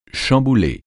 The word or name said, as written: chambouler